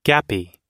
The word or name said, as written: gappy